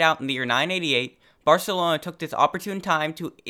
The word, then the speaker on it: Barcelona: And no, that's not just my lisp, that's how Barcelona is actually pronounced.